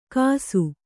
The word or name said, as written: ಕಸು